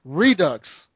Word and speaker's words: Redux: Redo. – Yeah. – You don't tell Americans how to fucking say something. – Metro Redo. – We tell you how to say it. Redux.